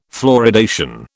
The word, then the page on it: fluoridation: British English